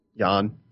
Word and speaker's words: Yan: Peter Yan. Is that how we're pronouncing it? – Yan.